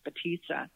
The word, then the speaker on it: Potica: Potica.